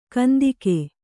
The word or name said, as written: ಕಂತೆಗ